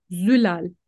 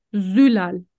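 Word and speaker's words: Zülal: Als Wenn man sich vorstellt, wenn man sich vorstellt, kann man sagen, es wird mit Z geschrieben, aber es wird mit wie scharfes S ausgesprochen. Das heißt, mein Name wird geschrieben mit Z, aber wird ausgesprochen Sülal.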